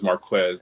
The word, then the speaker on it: Marquez: All right, well, the name is Marquez, by the way.